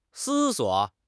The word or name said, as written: 思索